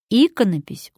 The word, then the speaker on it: иконопись: Здесь разные позиции ударения, и в слове «иконопись» ударение падает на первый слог.